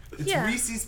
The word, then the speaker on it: Reese's: Reese's because it rhymes with feces.